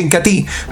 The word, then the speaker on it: Incatea: Esta semana lo puede conseguir acá directamente en el punto de venta en el municipio, o pueden solicitarlo también el Incatí. Se escribe Incatea, pero se pronuncia Incatí.